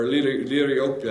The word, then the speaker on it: Liriope: Or Liriope is probably how it's pronounced.